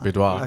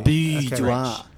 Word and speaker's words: boudoir: Boudoir. – Okay, thank you. – Boudoir.